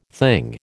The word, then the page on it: thing: American English